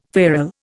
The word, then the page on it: feral: Indian